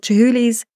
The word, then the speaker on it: Chihuly: And we got to see this exceptional, exquisite exhibition of Dale Chihuly's glasswork.